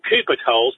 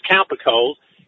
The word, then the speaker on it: Cowper-Coles: A Bilderberger, Sherard Cowper-Coles, it's spelled Cooper-Coles, but it's pronounced Cowper-Coles.